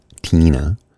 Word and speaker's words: Tina: Tina was a nickname, pronounced T-E-E-N-A, Tina, which was a variation of Teeny, which was a childhood moniker.